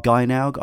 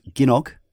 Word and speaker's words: Gynoug: I always used to call it Gynaug. I've recently been told it's Ginog.